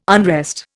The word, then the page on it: unrest: Indian